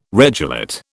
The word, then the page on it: regelate: British English